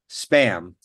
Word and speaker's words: SBÄM: They're apparently pronounced spam, but spam. It's not spam with a P, like the food that you eat that's originally from Hawaii in a tin can, but spam with a B.